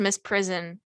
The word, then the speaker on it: misprision: I found out why we're unfamiliar with the term misprision of felony is because I think it's just pronounced misprison.